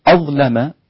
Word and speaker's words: أظلم: أظلم، غلظ ورش اللام في أظلم فيصير النطق بها أظلم هكذا، وورش غلظ اللام المفتوحة بعد ضاء ساكنة أو مفتوحة.